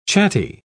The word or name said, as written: chatty